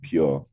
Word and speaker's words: PUR: Is it just pur? It's P U R. Is it pronounced pur? Or how do you pronounce it? – So we were pronouncing it like pure. Pure.